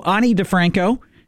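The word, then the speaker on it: Ani DiFranco: We also heard from the Police, Ani DeFranco is how you say that.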